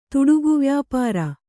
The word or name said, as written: ತುಡುಗುವ್ಯಾಪಾರ